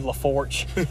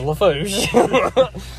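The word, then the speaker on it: Lafourche: I've heard that so many times, Bayou LaForge. It's like, oh, it's LaForge. And it's like, no. – No, that's LaForge. – It's LaFouche. But I understand because they want to, like, spell it phonetically, mm-hmm. – but it's not.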